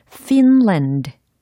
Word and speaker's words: Finland: 철자는 F-I-N-L-A-N-D. 이렇게 구성이 되어 있습니다. 그럼 핀란드가 아니고 피로 시작을 해야 된다라는 것은 감을 잡으셨죠? 자, 나름의 발음을 한번 소리를 내보세요. 하고 계시죠? 네. 핀란드. 영어적으로는 이겁니다. Finland. Finland.